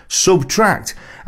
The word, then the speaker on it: SBTRKT: The artist name is pronounced Subtract, as in the arithmetic operator, and not by reading out the letters individually, as you did in this instance.